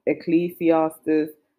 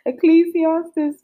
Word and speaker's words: Ecclesiastes: King Solomon, the wise King Solomon, even in Ecclesiastes, oh, that word, I'm surprised, I'm glad I pronounced it properly. Ecclesiastes chapter 8, verses 16 to 17.